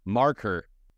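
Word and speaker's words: Markhor: Oh, we're saying it way incorrectly. – How do you say it? – Marker. – Marker? – Like a marker.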